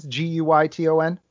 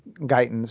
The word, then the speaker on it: Guyton: So how do you spell that? Like it sounds, Guyton. – Guyton, exactly. – It's not like I've seen a Gaiton pronounced Guyton, so I just wanted to make sure. Guyton, as it sounds.